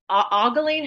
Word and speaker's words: ogling: I'm ogling or ogling. How do we pronounce it? – Ogling her ankles.